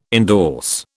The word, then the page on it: endorse: British English